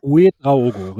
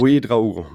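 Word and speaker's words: Ouédraogo: Uwe Draogo, so. – Uwe Draogo, richtig. Uwe Draogo, Mann. – Mit Betonung auf dem E.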